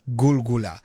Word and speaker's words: Gulgula: G-O-L, G-O-O-L, Gul, G-O-O-L-A. – Yeah. – Gulgula. But then this song is spelled as G-U-L-G-U-L-A.